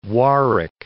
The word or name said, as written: Warwick